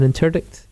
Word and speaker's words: interdict: One thing he did was declare an, is it pronounced, interdict? An interdict against Scotland. – Interdict, yeah.